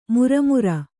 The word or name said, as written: ಮಿರಿಮಿರಿ